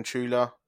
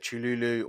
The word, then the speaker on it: Cthulhu: If I'm pronouncing that right, there's about 15 different pronunciations. Some call him Chula, or you get Cthulhu, or you get Tula.